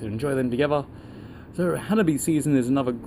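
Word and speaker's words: Hanabi (花火): Hanabi is Japanese for fireworks.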